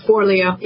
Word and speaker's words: Forleo: Forleo, yeah. – What did I say her name wrong? – Forleo. – That's what I said.